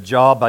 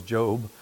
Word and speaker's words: Job: I thought it said Job. I didn't want it, so I just kind of jumped around it. And I found out it's pronounced like two O's, Job.